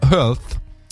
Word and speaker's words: Health: È il risultato di uno studio della National Institute of Health. Si pronuncia health, salute.